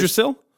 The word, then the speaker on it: Yggdrasil: Yggdrasil? – What? – Yggdrasil? Y-G-G-D-R-A-S-I-L-L.